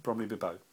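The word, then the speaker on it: Bromley-by-Bow: Because you know the underground stop Bromley by Bow? – Yeah. – Bromley by Bow? Mm. My mum pronounces it Bromley Bibbo. Bromley Bibbo.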